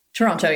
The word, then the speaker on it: Toronto: Toronto. Yeah. – Toronto. Okay. Do I say the 2 T's? – You know, it's said that if you're from Toronto, you don't say the second T, you just say Toronto.